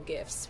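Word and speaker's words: GIF: And Giphy is the world's largest search engine for all GIFs, whether you pronounce them GIFs or GIFs. We're hard G's, Team Hard G over here.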